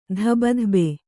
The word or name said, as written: ಧಬಧಬೆ